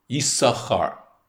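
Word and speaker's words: Yisachar (יששכר): There's something that we take for granted, and that is that the name Yisachar is not pronounced the way we would expect it to, given how it's spelt. It's spelt with two sins, Yud Sin Sin, Kaf Resh or Chaf Resh, and yet we only seem to pronounce one of those sins, Yisachar. The other one seems to be silent.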